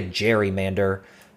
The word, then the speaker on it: gerrymander: And one critic said it looked like a salamander, and another said, no, it looks like a gerrymander. For some reason, mispronouncing the G sound.